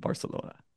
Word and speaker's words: Barcelona: Paris, San Sebastian, and Barcelona.